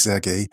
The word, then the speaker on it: Serhii: Zunächst einmal der Ukrainer, Serhii, wie du sagst, es spricht sich Sergei. Im Ukrainischen das H wird wie das G gesprochen.